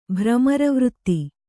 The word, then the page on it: ಭ್ರಮರವೃತ್ತಿ: bhramara vřtti